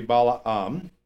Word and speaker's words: Balaam: He sent messengers therefore unto Balaam, should be Balaam, that's the way you pronounce it in Hebrew,